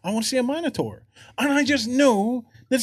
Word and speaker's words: Minotaur: So is it pronounced Minotaur or Minotaur? – No, it's Minotaur.